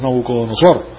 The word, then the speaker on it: Nabucodonosor: Pero en la vida de Nabucodonosor, no lo puedo pronunciar hoy en día, pero es Nabucodonosor, el síndrome es demostrado de una manera vívida,